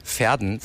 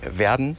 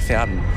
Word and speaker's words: Verden: Der größte Kritikpunkt, glaube ich, den wir immer bekommen, ist, dass die Stadt Verden falsch ausgesprochen ist. Weil es heißt dann wirklich Verden. Genau andersrum. Wir sagen Verden und es heißt in Wirklichkeit Verden.